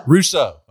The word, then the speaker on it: Russo: Russo.